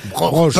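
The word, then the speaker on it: Broch: Alors, je sais pas si on prononce broor ou broche. – Broche. – Parce que parfois. – Ça dépend s'il y a un umlaut sur le O. – Oui. – Il n'y a pas de umlaut sur le O.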